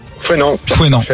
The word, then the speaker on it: Fouesnant: Ça se prononce Fouesnant ou Fouesnant.